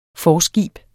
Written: forskib

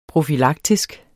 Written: profylaktisk

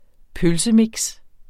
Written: pølsemix

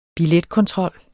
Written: billetkontrol

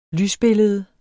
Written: lysbillede

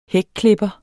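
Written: hækklipper

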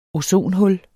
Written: ozonhul

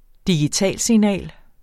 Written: digitalsignal

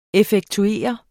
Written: effektuere

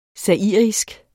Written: zairisk

